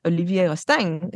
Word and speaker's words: Olivier Rousteing: And we actually had a really great conversation with Olivier Rousteing, who is the creative director of Balmain.